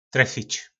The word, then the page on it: trefic: po nasimu